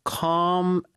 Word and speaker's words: calm: 자, calm 발음 다시 한번요. – Calm. – 좀 길게 calm, Calm. – C-A-L-M.